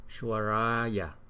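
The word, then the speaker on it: Valokiteśvarāya: Rồi chữ S mà có dấu sắc trên đầu là mình đọc là sh, Shvaraya, Shvaraya.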